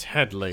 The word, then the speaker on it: Hedley: The only thing I can think of is the Hedy Lamarr jokes, like the running joke throughout. – Where they mispronounce his name. – Mm-hmm. Is that what you're talking about? – It's Hedley.